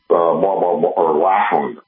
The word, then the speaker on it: Lackland: Went to the academy at Lakeland Air Force Base, Lackland. I mispronounced that. I misspoke. Lackland Air Force Base.